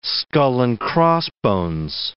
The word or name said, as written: skull and crossbones